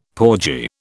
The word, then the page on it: porgy: British English